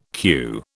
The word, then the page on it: queue: British English